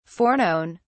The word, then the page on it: foreknown: British English